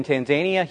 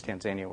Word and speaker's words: Tanzania: So, and some people pronounce it Tanzania, but it's Tanzania where we are.